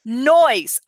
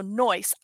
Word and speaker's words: Noyes: Noyes. Okay. And it's with a soft S. So Noyes.